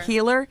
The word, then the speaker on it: Keillor: Garrison Keillor, I think that's how you say it. – Keillor. – Keillor.